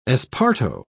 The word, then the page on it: esparto: British English